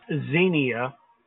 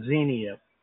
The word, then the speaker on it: Xenia: And yeah, I think it's pronounced Xenia. Kind of like there was a TV show, Xena, Warrior Princess, something like that. So anyway, long E, and it sounds like it starts with a Z, Xenia.